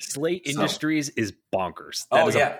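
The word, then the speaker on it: Sla Industries: So, Slay Industries. It's spelled SLA.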